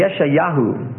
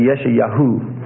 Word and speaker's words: Yeshayahu: The Hebrew, if you were to pronounce the Hebrew of the name Isaiah, let's talk about his name. It would be Yeshayahu. Actually, Yeshayahu. Yeshayahu. Y-E-S-H-A-Y-A-H-U.